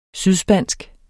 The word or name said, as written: sydspansk